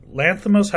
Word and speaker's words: Lanthimos: So the next movie I saw was Begonia, Yorgos Lanthimos. How do you say the last name? – Yeah, I think you got it.